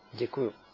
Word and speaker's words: Děkuju: And I will get this wrong, of course, but I want to say Drayuhu, Druyuhu, How do you say thank you? – Děkuju. – Děkuju.